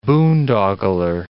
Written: boondoggler

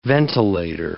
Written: ventilator

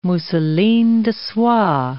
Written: mousseline de soie

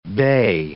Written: bey

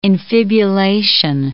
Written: infibulation

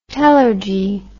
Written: telergy

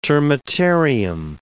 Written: termitarium

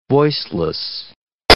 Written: voiceless

